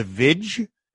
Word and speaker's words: Viy: And he gave me a tape of this 1967 horror folktale called The Vij. It's spelled V-I-Y, but it's pronounced Vij, apparently.